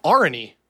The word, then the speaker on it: Areni: Arni, A-R-E-N-I, that's real easy to say.